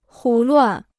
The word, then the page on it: 胡乱: hú luàn